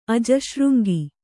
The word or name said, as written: ಅಜಶೃಂಗಿ